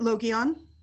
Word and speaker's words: Logion: How is it pronounced? Is it Logion or Logion or what? – I pronounce it Logion.